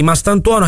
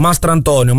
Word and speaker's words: Mastantuono: Secondo me, proporrei una raccolta fondi tutti quelli che pronunciano in maniera errata il nome di Mastantuono, chiamandolo Mastrantonio, Mastrantuono, Mastrantuono e anche Abantuono, Abatantuono.